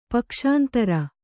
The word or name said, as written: ಪಕ್ಷಾಂತರ